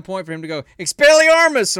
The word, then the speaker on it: Expelliarmus: To say it completely right, you have to say it with slight accent. Expelliarmus.